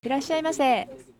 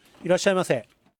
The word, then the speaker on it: いらっしゃいませ: いらっしゃいませ. – いらっしゃいませ. – いらっしゃいませ.